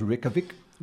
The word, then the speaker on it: Reykjavik: The name was given, and it's R-E-E-K-A-V-I-C, it looks like Reykjavik, it's pronounced Reykjavik, Reykjavik, was given to the city by Nordic settlers around AD 870, and translated it means Bay of Smokes.